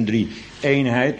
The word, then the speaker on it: drie-eenheid: En uiteindelijk dus een drie-eenheid met de klemtoon op eenheid vanzelfsprekend.